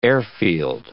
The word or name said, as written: airfield